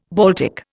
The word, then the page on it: Baltic: Indian